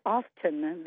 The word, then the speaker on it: often: And then the other one that I notice is everyone now says often. They pronounce the T. And we were told since kindergarten that you don't do that. It's silent.